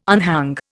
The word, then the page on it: unhang: Indian